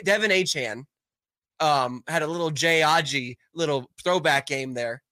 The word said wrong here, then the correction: Ajayi: Are you saying Jay Ajayi? – I can't pronounce it.